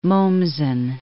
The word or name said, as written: Mommsen